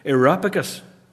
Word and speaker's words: Areopagus: And then this other place, I got the pronunciation wrong a couple of times, Areopagus.